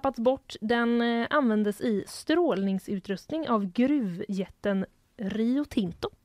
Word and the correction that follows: Rio Tinto: Oj vad svenskt jag uttalade det. – Rio Tinto. – Rio Tinto.